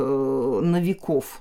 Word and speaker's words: Новиков: Это вот э, Новиков. Новиков, угу. вот известный просветитель в русской культуре, как раз носит фамилию с ударением на последний слог.